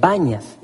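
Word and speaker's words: Banias: Pero los árabes no pueden pronunciar la P. Entonces ellos pronuncian sus P's como B's. Y le dicen Bañas.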